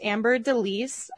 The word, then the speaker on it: Amber Délice: But with us today, we have our lovely guest, Amber Délice.